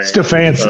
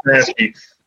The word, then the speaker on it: Stefanski: And I feel like um, our coach, uh, Kevin, I'm going to butcher his last name Stefanski. Uh, Stefanski. – Stefanski.